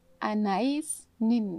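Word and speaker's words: Anaïs Nin: A nice nin. Ich hoffe, ich habe das richtig ausgesprochen. – Ja, ich hätte das auch so ausgesprochen. – Genau, ein wundervolles Zitat von Anais Nin.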